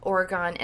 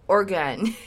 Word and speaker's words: Oregon: I just want to talk just a few seconds about Oregon as a state, or Oregon. Oregon. I was told several times that I was pronouncing it wrong.